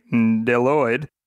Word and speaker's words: Bdelloid: You're only hearing me say Deloid, but that name is spelled B-D-E-L-L-O-I-D from the ancient Greek word for leech, which is what these guys are shaped like.